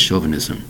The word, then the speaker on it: chauvinism: The word is chauvinism. Three syllables spelled C-H-A-U-V-I-N-I-S-M. Chauvinism. Again, chauvinism. Chauvinism. C-H-A-U-V-I-N-I-S-M.